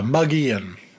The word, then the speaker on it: Mogollon: Yeah, Mogollon.